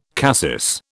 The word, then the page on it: cassis: British English